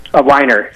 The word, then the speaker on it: Weiner: And is it Jennifer Weiner or Jennifer Weiner? – Weiner. – It is Weiner.